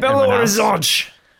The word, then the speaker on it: Belo Horizonte: Their road goes to Belo Horizonte. Hope I'm saying that right. – Belo Horizonte!